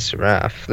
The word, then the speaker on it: Seraph: It just says Seraph. Let me check. – Is it like an RPG? – Vampire game? Yeah. A Seraph is an angelic being regarded in... Okay. Seraph. That's how you say it.